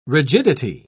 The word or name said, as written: rigidity